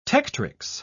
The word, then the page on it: tectrix: British English